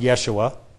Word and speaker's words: Yeshua: So Joshua, which could be pronounced Yeshua in the Hebrew, you see the Greek form there, and when you translate that Greek form into English, you have the name Jesus.